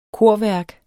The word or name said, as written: korværk